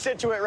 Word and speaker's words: Scituate: So let's start with CBS News correspondent Tom Hansen, who's live from Scituate, Massachusetts, which is just south of Boston. – How bad is it, Tom? Hey there, Adriana. Well, as you can... Congratulations. You pronounced Scituate right.